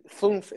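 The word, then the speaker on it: fünf: Funf is how you say five in German.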